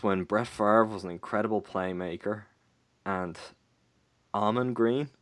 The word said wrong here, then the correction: Ahman: Almond. I'm probably pronouncing it wrong. A-H-M-A-N? – A-H-M-A... Oh, Amon. Amon Green.